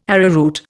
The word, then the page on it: arrowroot: Indian